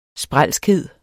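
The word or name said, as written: SPRÆLSKHED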